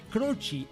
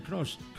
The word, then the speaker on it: Croce: Croce, así se pronuncia porque era italoamericano. Mm-hmm. Hay mucha gente que lo pronuncia Croce como el futbolista. – Sí, pues, no, es Jim Croce.